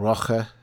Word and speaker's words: Rocha: We have a marvellous band called Rocha. Have I got the pronunciation of that right? – That sounds great to me.